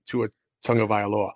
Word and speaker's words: Tua Tagovailoa: Like, hey, by the way, Roger Goodell, see how I pronounce that? That's how you say Tua Tagovailoa.